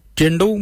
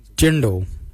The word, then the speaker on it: djendal: Jindo means white. Jindo. Jindo is spelled D-J-E-N-D-A-L.